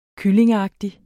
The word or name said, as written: kyllingeagtig